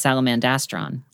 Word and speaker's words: Salamandastron: I think it's an N. – You think so? – Well, because I always heard it as Salamandastron. But, like, I also never read any pronunciation guide because I'm a monster. – This is like the Berenstain Bears thing all over. – Indeed. – Yes. It ends with an N as in Nicholas.